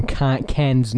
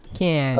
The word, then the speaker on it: Cannes: Well, you should read up on Cannes News more often. – I don't think it's pronounced that way, but okay. – Cannes.